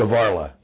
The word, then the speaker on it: Skvarla: Skvarla.